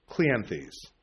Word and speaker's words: Cleanthes: Of course, he's referring to the poets or the philosophers, how do you pronounce this, Aratus and Clinithes, Clinith, I can never pronounce Greek names. Cleanthes. There we go.